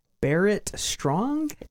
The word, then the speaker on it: Barrett Strong: It's called it's called Money, That's What I Want, and it's by Barrett Strong? – Yeah.